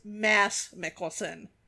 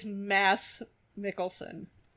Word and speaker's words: Mads Mikkelsen: It is Mads Mikkelsen. Okay. I don't know what happened to that D. Is that mysterious, like, silent D? yeah. It's Mads Mikkelsen.